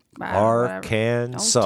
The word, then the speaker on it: Arkansas: And while you're at it, make sure you're pronouncing it right, because they do. – Arkansas. – Don't tick off the Arkansans. – No. – I was born there. – That's true. You were. – I also married my cousin. – Oh. – We won't get into that. Yeah. What's the proper pronunciation of Arkansas? – That's it.